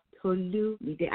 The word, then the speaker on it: TolumiDE: And my name is pronounced TolumiDE. I have a pronouncer on my website as well, if you like to know how to pronounce it. I know that. I know it's a bit of a tongue twister, but if you look at that pronouncer, it will help you.